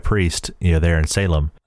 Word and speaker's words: Salem: You know, at that time, it was known as Salem or Salem, right, if you're going to pronounce it properly.